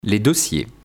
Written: les dossiers

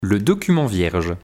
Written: Le document vierge

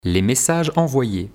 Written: les messages envoyes

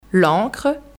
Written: l'encre

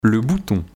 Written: Le bouton

